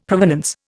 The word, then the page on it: provenance: Indian